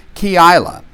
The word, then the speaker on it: Keilah: When David was told, look, the Philistines are fighting against Keilah. That's how you pronounce it, I looked it up. Keilah.